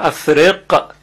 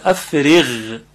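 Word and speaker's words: أفرغ: به لحاظ مهارت تجوید و تلفظ و اداء حروف، کلمه افرغ غین داره که باید دقت کنیم افرغ اینطوری خونده نشه، چون این قافه و این غلطه. افرغ غلطه، افرغ، افرغ دقیقا مثل غینی که در کلمات المغضوب هست، غیر المغضوب، غیر المغضوب.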